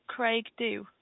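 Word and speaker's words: Creag Dhubh: C-R-E-A-G. – D-H-U-B-H. – Yes, okay. – Yeah. – And that was, how do you pronounce that, Craig Dew? – Craig Dew. Everybody thinks it's Craig Dew.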